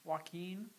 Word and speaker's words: Joaquin: Joaquin. – Joaquin.